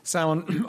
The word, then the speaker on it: Samhain: Chief among these was the fire festival called Samhain, pronounced Samhain, observed at harvest time to mark the Celtic New Year.